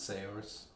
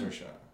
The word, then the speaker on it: Saoirse: Is that how you say it? Not Sayers? – Yeah. It's a... – Cersei. – Cersei. – Oh, yeah. Cersei. Actually, it is. You kind of say it like Cersei.